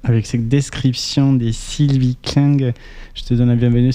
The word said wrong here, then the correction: Klinger: C'est Klinger, mais tu Klinger. – Fais comme tu peux.